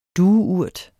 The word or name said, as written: dueurt